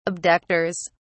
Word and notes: abductors: British English

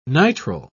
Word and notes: nitrile: British English

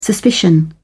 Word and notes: suspicion: British English

